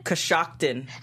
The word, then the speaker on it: Coshocton: Coshocton. – Coshocton. Wow. That sounds like a fake town. – All right. Well, he wants to know when you're coming back to Coshocton.